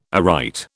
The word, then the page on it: aright: British English